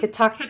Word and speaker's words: Catoctin: It's always Catoctin. Catoctin. If you put the accent on the second syllable, you will say it right.